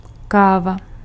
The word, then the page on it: café: France (Île-de-France); [ɛ̃ ka.fe]